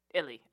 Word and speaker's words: Illi: And Instagram, at Sir, S-I-R, _ Fly Guy, F-L-Y-G-U-Y, _ Illy, I-L-L-I.